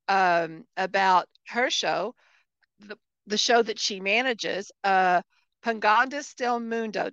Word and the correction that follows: Paganos del Mundo: Did I say that correctly? – Unfortunately not. – Okay, say it again. – Paganos del Mundo. Paganos del Mundo, Pagans of the World,